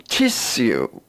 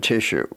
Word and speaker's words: tissue: And in this grouping, the sh is spelt S-U and S-S-U. The words are sure, ensure, assure, fissure, issue, and tissue. What would be the British pronunciation of the last two words? – Well, in England, they would say issue and tissue, a little different from our issue and tissue.